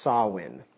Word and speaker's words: Samhain: It looks like Samhain. It's pronounced Samhain. So it's kind of strange. It's S-A-M-H-A-I-N. And so when you hear me say Samhain, that's the pronunciation of this word here.